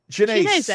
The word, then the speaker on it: Chianese: I think how do we say this? Chianzi, Chianese. – Chinese.